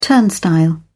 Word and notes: turnstile: British English

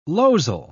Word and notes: losel: British English

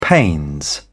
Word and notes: pains: British English

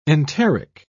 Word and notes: enteric: British English

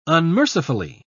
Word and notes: unmercifully: British English

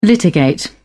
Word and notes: litigate: British English

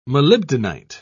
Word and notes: molybdenite: British English

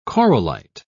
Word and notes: corallite: British English

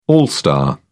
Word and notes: all star: British English

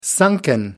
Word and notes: sunken: American English

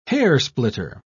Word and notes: hairsplitter: British English

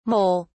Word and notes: mole(s): British English

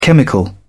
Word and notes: chemical: British English

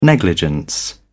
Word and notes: negligence: British English

